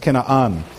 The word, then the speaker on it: Canaan: He picked 12 men, leaders from each of the 12 tribes of Israel, to go out and inspect, you know the story, the land of Canaan, in Hebrew pronounced Kena'an.